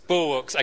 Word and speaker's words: bulwarks: How do you say that word? Bulwarks. Okay. I thought I was way off.